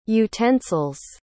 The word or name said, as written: utensils